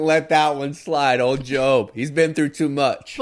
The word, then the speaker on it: Job: But the seminarian inside my brain, because I used to want to be a preacher, will not let that name pronunciation as Job go by. That is Job.